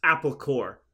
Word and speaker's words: Corps: It's pronounced Apple Corps because it's a pun.